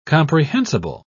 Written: comprehensible

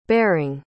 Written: bering

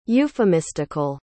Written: euphemistical

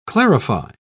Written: clarify

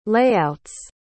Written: layouts

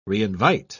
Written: reinvite

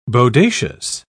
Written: bodacious

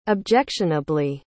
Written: objectionably

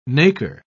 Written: nacre

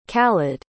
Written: khaled

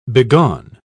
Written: begone

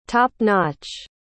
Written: top-notch

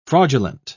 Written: fraudulent

